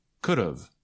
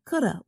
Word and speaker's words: could've: But native speakers usually shorten these with contractions, so it sounds like this. Should've, could've, would've. – And sometimes native speakers reduce or shorten the pronunciation even more. So it sounds like this. Shoulda, coulda, woulda.